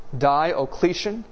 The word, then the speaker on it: Diocletian: Its most serious persecution was under the emperor Diocletian. You can just remember the first syllable of his name is Di, and it will help you remember. Diocletian.